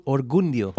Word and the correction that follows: oriundo: Oriundo. Oriundo.